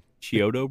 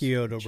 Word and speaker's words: Chiodo: How do you say it? The Chiodo brothers. – Chiodo, brothers. yeah.